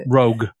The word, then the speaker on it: Roeg: Roeg? We going that way? – You can. I go with the hard G. I don't know. – Roeg.